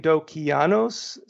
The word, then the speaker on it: Dokianos: Well, I don't know if I'm that young, but first of all, Tolis, it's spelled T as in Tom, O-L-I-S as in Sam. Last name Dokianos, spelled D as in David, O-K-I-A-N as in Nancy, O-S as in Sam.